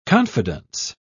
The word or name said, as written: confidence